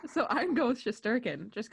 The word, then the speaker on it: Shesterkin: Shesterkin? – Putting the emphasis on the wrong syllable. – Really? – Shesterkin. – Oh, Shesterkin.